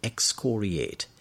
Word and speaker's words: excoriate: We're looking at this word excoriate. E-X-C-O-R-I-A-T-E. Note the pronunciation on the second syllable. Excoriate. Excoriate.